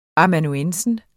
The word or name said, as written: amanuensis